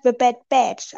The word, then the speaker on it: The Bad Batch: Übrigens, ich wollte dich gerade korrigieren, weil wir Badboard, weil das heißt The Bad Batch. Also richtig ausgesprochen heißt es The Bad Batch, die Serie.